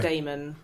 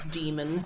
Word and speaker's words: Demon: Please don't pronounce it Damon. – Yeah. – It's Demon.